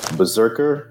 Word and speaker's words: BRZRKR: As you heard in the singing intro, today is 8 Berserkers Berserking, which means we're unwrapping Berserker number 1 from Boom Studios in 2021. Now for a brief history of the series and the creators. Berserker, which is spelled B-R-Z-R-K-R,